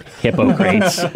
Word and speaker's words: Hippocrates: Hippocrates. – I would have pronounced this wrong because I just see hippo. – Hippocrates.